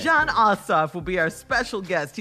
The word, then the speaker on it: Ossoff: Coming up next hour, Georgia Democratic Senator, well, Senate candidate, John Ossoff.